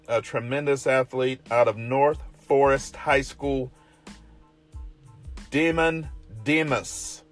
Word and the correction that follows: Damon Demas: I'm going to try that again. Damon Demas. That's probably it. Yeah, I'm not going to mess that up. Put the wrong emphasis on the wrong syllable. But I got it fixed.